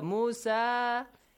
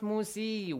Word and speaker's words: Musa: So Musa, it's not pronounced Musi. We have a letter ya, but that's silent. It's written but not pronounced because this is the seat or chair for Mr. Hamza.